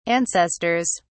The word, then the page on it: ancestors: British English